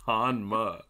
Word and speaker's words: Hanma: Like, it's not like it's like a Japanese pronunciation. It's Hanma.